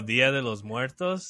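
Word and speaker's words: Día de los Muertos: like, I think it's called like Dias de Muerte or something like that. I said that wrong for sure. – Oh, Dia de los Muertos?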